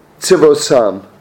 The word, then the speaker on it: tzivosam: It says, when we left Israel, we left according to our tzivosam. That's how you say it in Hebrew.